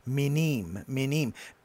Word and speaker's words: Minim: Now, they spell it in that M-I-N-I-M. It's probably M, actually, in pronunciation, it's probably N-I-Y-N-I-Y-M. Minim.